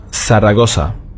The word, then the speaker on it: Zaragoza: Zaragoza, das wird ausgesprochen wie ein TH auf dem spanischen Festland. Hier allerdings haben wir nur ein scharfes S. Zaragoza.